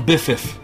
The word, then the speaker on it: biffiff: A biffiff. Instead of them saying BFF, they pronounce it biffiff.